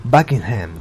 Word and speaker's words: Buckingham: Buckingham.